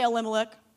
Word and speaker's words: Elimelech: So Naomi and her husband Elimelech, everyone say Elimelech.